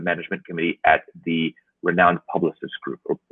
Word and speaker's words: Publicis: Or how would we say in French? What would be a good pronunciation for that? – It would be right. It would be Publicis Group. – Publicis. Okay, maybe we'll change the U just a little bit, but kudos to the French for allowing us to pronounce their name.